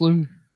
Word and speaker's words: Louis: No. It's pronounced Louis. Yeah. – There's an S on it. – Yeah, it's pronounced Louis. – All right, well. – He's Dutch.